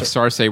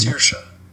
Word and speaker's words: Saoirse: Of Sarsay Rohans. – Sarsay Rohans. For the love of God, please pronounce it correctly. So here it is right now. – Okay. Give your sound. – Saoirse. Saoirse. – Saoirse. Saoirse Ronan.